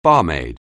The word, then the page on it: barmaid: anglais